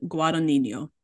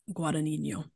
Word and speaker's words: Guadagnino: I figured out how to pronounce his last name. Luca Guadagnino, the director. Guadagnino. It's Italian.